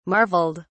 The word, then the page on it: marveled: British English